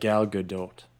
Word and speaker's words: Gal Gadot: One time she was on a late night show and she was explaining how to say it. And she said something about the T being light, a light T. – Yeah. It's almost like, I think it's like Gal Gadot.